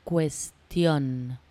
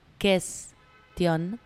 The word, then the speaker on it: cuestión: en español se escribe con C, question, con la misma C de cuento, con la misma C de cuerda, y por eso se pronuncia ques, ques.